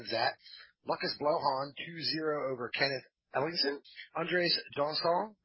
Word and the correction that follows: Janssen: versus, I know I butchered that, I'm sorry. – Yeah. – Versus Marcio Carvalho. Carvalho wins 2-0. – I'm pretty sure it's Janssen.